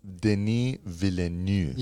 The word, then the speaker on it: Villeneuve: It's pronounced Denis Villeneuve.